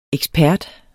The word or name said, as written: EKSPERTERNE